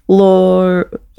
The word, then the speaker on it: Loire: Loire Valley.